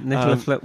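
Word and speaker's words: Nicolas Flamel: Yeah, Nicola. Well, we now know that that's how it's pronounced. – We've at least. – All been saying it wrong.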